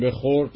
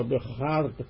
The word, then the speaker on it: بخرد: بنابراین می بابلی سرخ در جام زرد تهمتن به روی زواره بخرد، که در واقع این به روی او بخرد که حالا ما امروز میگیم بخورد، اینجا بخرد تلفظ میشه که با زرد هم قافیه شده است، این همون چیزیست که حالا اصطلاحاً باده نوشان در دوره‌های جدید میگوین به سلامت فلان کس یا به سلامتی فلان کس.